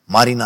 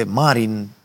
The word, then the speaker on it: Marin: Uh, Marin ar fi pe românește, Marin, se poate pronunța oricum vreți, da?